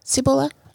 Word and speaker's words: Cibola: Cibola. – Oh my goodness, that is right. Wow. That's how you say it. Cibola. Yeah. Cibola is correct.